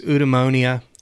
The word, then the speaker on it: eudaimonia: Eudaimonia, I think philosophers tend to call it eudaimonia, which is kind of a bastardized pronunciation of it.